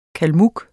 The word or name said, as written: kalmyk